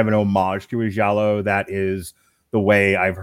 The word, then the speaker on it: giallo: It's been described as a giallo, if I'm pronouncing that right. Yeah. G-I-A-L-L-O. – That's close enough to the correct pronunciation. We can go with that.